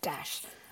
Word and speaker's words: Desch: She's like, how do you say his last name? I'm like, Desch. I'm like, she's like, I'm channeling Rod Desch.